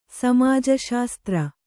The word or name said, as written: ಸಮಾಜಶಾಸ್ತ್ರ